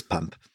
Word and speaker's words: Pump: Ähm, aber Sprechen wir es richtig aus? Ist es Pump? Ist es Pump. Gott sei Dank.